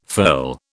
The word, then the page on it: furl: British English